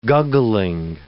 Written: guggling

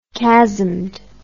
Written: chasmed

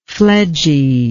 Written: fledgy

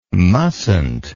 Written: mustnt

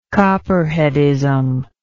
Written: copperheadism

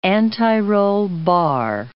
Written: anti-roll bar

